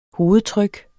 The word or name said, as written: hovedtryk